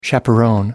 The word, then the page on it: chaperon: British English